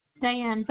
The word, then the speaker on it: Diane: Diane Valenzuela, D-I-A-N-E, Valenzuela, V-A-L-E-N-Z-U-E-L-A. – You said G-U? – It's V-A-L-E-N-Z as in zebra, U.